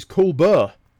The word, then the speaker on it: Colbert: First, I wish to thank Michael Bailey, who emailed me in following last issue, telling me about Stephen Colbert, which he pronounces Colbert.